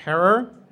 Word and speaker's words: Herer: Really, really uplifting and Jack Herer, rhymes with terror, he got really high.